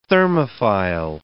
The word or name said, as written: thermophile